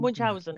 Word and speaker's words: Munchausen: Is it Baron von Munchausen? – Munchausen. – Yes.